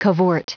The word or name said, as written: cavort